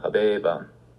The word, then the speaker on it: habebam: Gladium habebam. I used to have a sword. You see what happened here? Habere gets a long E before the BA.